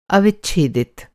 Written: अव

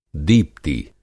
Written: दीप्ति

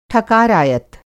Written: ठकारायत

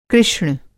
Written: कृष्ण

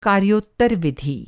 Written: कार्योत्तर

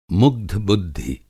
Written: मुग्ध-बुद्धि

